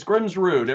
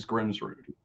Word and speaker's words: Grimsrud: It's Grimsrud. Everybody wants to say Rudd because they don't want to say rude, but it is Grimsrud.